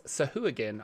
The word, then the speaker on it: sahuagin: The D&D Beyond, Matt Mercer says Sahuagin on D&D Beyond.